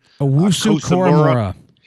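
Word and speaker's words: Owusu-Koramoah: Owusu Koramora.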